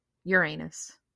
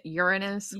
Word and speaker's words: Uranus: Actually, I think they're pronouncing it differently now. Isn't it Uranus? – Uranus. – Mm-hmm. Uranus. So Uranus.